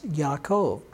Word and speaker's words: Yaakov: Jacob is pronounced Yaakov.